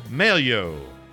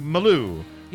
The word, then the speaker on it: Mallou: That's from Merle Maloo. M-A-L-L-O-U. Maloo.